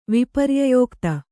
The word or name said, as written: ವಿಪರ್ಯಯೋಕ್ತ